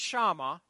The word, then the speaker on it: Shema: It's the Jewish Shema is how it's pronounced, I believe.